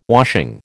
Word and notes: washing: American English

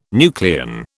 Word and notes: nucleon: British English

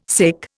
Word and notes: sic: Indian

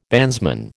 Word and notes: bandsman: American English

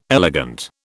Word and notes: elegant: British English

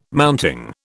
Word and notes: mounting: British English

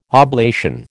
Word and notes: oblation: American English

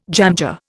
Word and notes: jamjar: Indian

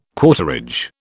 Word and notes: quarterage: British English

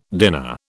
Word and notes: dinner: British English

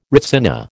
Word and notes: retsina: American English

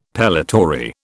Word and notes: pellitory: British English

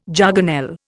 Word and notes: jargonelle: Indian

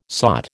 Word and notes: sot: American English

